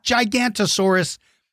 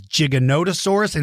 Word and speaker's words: Giganotosaurus: But all I can tell you is you better be careful if you happen to live around Giganotosaurus. So that's it. It is not Gigantosaurus. It is Giganotosaurus.